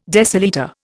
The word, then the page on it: decilitre: Indian